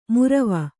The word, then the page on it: ಮುರವ: murava